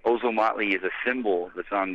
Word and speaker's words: Ozomatli: You pronounced that perfectly, actually. It is Ozomatli.